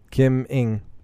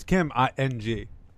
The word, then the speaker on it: Kim Ng: Kim Ng. – Yeah, it's Kim Ng, and she's a person who's gotten a lot of press as being a good one.